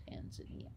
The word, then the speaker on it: Tanzania: It's pronounced Tanzania.